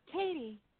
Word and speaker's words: Katie: Katie. – Oh, Katie. K-A-T-I-E.